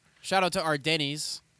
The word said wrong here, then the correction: Ardens: Ardens. – Ardens. Shout out to Ardens.